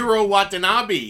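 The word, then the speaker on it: Shinichirō Watanabe: Shinjiro Watanabe.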